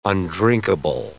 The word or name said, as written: undrinkable